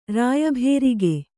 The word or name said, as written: ರಾಯಭೇರಿಗೆ